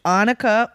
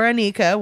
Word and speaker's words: Anicka: There's no S in your name at all. – Or H. – A-N-I-C-K-A. Which Anika or Anika would you know, you could assume either of those.